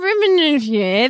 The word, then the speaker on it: Riihimaki: Lauren Rimmenier. Thank you. – That's exactly how you say it. You nailed it.